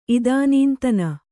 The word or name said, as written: ಇದಾನೀಂತನ